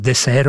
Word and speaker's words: Désert: Désert geschreven, maar uitgesproken als desert ook.